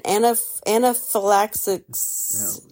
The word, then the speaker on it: anaphylaxis: Charles Olson suffered an anaphylaxis... Did I say it right? – Yeah, okay.